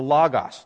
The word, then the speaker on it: logos: Well, it's the Greek word, a logos, L-O-G-O-S. We pronounce it logos.